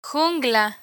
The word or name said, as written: jungla